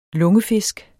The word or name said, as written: lungefisk